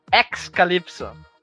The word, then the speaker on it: Xcalypso: Como, como é que é a pronúncia? Excalipso.